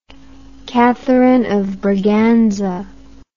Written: Catherine of Braganza

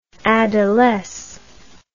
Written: adolesce

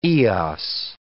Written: Eos

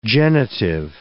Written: genitive

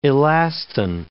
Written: elastin

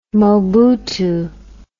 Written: Mobutu

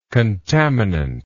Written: contaminant

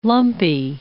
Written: lumpy

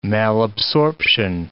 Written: malabsorption

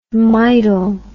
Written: midol

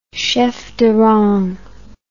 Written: chef de rang

